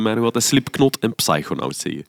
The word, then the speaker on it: Psychonaut: En ik Zwaar, heb ook uh, tien jaar lang psychonaut gezegd. Ja, goed. Tot iemand zei, nee, het is psychonaut. psychonaut. – Je mocht die P niet uitspreken. Psychonaut.